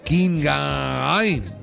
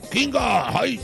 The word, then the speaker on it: Qinghai: en el Tour de Magnificent allá en Oingagi, en China. – ¿Dónde? – Qinghai. – Oh, siempre le cambias. – Oh, pues, ¿cómo lo pronuncias tú? – Qinghai.